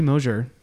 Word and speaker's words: Mosier: but, um, but Angie Mosier.